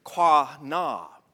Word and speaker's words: qanah: which is spelt Q-A-N-A-H. We pronounce it with a K, quanah.